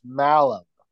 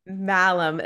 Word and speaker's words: Malum: I didn't want to mispronounce it because it could go the other way, but it's Malum. – Malum. I've seen the automated voices on some of like these Zooms say Malum.